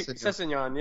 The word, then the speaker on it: Sessegnon: How do you pronounce it? Sessegnon? – Yeah, Sessegnon, yeah.